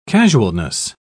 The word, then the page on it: casualness: British English